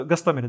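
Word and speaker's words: Гостомель: боюсь с ударением ошибиться, Гастомель, да, или Гастомель, Гастомеле а, вроде, правильно? – Гасто, Гастомеле, да, спасибо. А, сделанные американской компанией Maxar Technologies показали, что российское оборудование было вывезено где-то за последние 10 дней.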